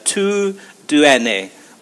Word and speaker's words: TUDN: So it, it's the the acronym is T U D N, but the way you pronounce it is uh, Tu Duene, which is uh, the Spanish translation of kind of uh, which is meant to be kind of your sports network uh, Deportes Sports Network, of course, network.